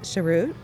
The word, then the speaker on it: Cheroot: And so when did you pick up, tell me how it's pronounced exactly so I don't chop it up. – Cheroot. – Cheroot. Okay. I didn't know, some people have been cheroot, and stuff like that, so.